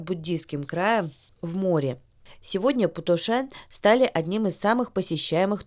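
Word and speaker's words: Путошен (普陀山): А в нашей сегодняшней передаче мы совершим виртуальное путешествие в горы Путошен. Пу произносится в третьем тоне, То читается во втором тоне, а Шен в первом тоне.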